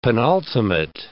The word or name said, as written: penultimate